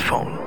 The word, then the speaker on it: Phaune: It's spelled P-H-A-U-N-E. But it's pronounced phone.